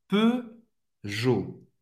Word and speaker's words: Peugeot: E ou em francês, mais uma vez, som fechado do E, faz a boca do O e fala E. E, E. Peugeot. Vous savez prononcer... Euh, non, vous pas prononcer au T, et vous allez prononcer nem au E também.